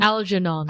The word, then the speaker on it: Algernon: Oh, how do you pronounce his name? – Algernon. – Algernon.